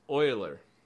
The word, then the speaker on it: Euler: How would you say that? – Euler. Euler, right? – His official name was Euler. That's the proper pronunciation.